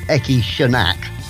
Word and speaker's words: Eki Shonak: Right, that, now that might sound a bit harsh, but the New York City art rockers Eki Shonak. I checked the pronunciation. That is okay. Correct. Eki right. Shonak.